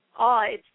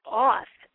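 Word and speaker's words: AO: There's a deity who presents herself to me, and her name is A-O, but not in the traditional A-O like you would say, Awe. It's pronounced, she pronounces it Auth,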